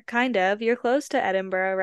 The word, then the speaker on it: Edinburgh: You're living in Scotland, which is near Edinburgh.